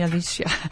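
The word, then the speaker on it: Alicja: It's very hard to pronounce it because it's A-L-I-C-J-A is pronounced like an European J-Yot, like Johann Sebastian Bach. So it's Alicia, but it's Alicia. You can call me Alicia.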